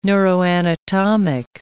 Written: neuroanatomic

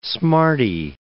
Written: smartie

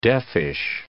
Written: deafish

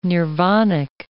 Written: nirvanic

